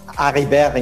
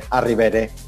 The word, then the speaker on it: Arribere: It's Arribere in French, but you can say Arribere. That's fine.